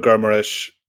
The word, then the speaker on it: Guimarães: He's playing he's done a decent job in midfield, but you brought in Bruno Guimarães from Lyon. – That's a nice pronunciation. Well done. Yeah. I like that. That was the hint of Portuguese.